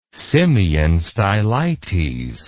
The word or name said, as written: simeon stylites